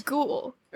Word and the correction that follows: Gohl: Or no, Gohl. Sorry, Gohl. – Gohl. Billy Gohl. Oh, well. The sheep fan. – Yeah. So his name is spelled G-O-H-L.